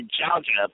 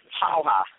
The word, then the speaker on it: Jauja: It's spelled like this, J-A-U-J-A. It sounds like it's going to be Jaja, but it's actually Haha.